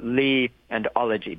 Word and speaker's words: Liology: And yeah, the way that I pronounce it is the Lilology Institute. So Li and ology, basically.